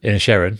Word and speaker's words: Inisherin: Inisherin. – Inisherin. Is that how you say it? – Yeah.